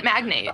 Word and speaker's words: magnate: Oh, he meant magnate. – I think he means magnate, but I don't know.